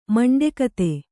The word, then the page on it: ಮಂಡೆಕತೆ: maṇḍe kate; dial.